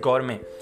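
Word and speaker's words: gourmet: it's pronounced as गौरमे कुछ लोग गुरमे भी बोलते हैं सो इट डिपेंड्स गौरमे या गुरमे अलग अलग प्रोनाउंसिएशन है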